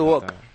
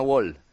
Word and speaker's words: walk: You'll Never Walk, no Wall. – Walk es lo de las sartenes. – Al cara del muro también va. – Es caminar. Exactamente. O sea, se escribe con L, pero no se pronuncia.